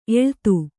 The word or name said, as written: ಎಲ್ಲಿತ್ತು